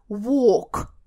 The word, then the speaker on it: walk: Посмотрите, когда мы говорим с вами гулять, а именно он гуляет, а не работает с детьми в парке, то звук у нас долгий О, он прямо такой в горлышке. Walk, walk, вот это гулять.